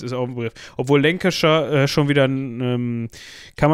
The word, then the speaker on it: Lancashire: das, das, das spricht man fast gar nicht mehr, dieses Shire spricht man gar nicht mehr richtig aus, man sagt Lancashire. Äh, also man, selbst die Engländer verwursten das ja hinten so, also das, das wird ja so pff, komplett weggekürzt.